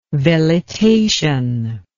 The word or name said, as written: velitation